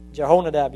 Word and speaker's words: Jehonadab: Is that how you say it? Jehonadab? Jehonadab, yes, I said it right.